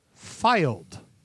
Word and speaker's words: Fylde: AFC filed, like filing something in a cabinet. – That's how you spelled it. – That's how you pronounce it. Because England, Mel. Because fucking England. We talked about this earlier. It is spelled F-Y-L-D-E. Filed is how it's...